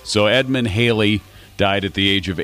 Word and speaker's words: Halley: English astronomer Edmund Halley, because we do say Halley's Comet, but I believe the pronunciation of his name is actually Halley.